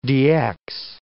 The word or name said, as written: DX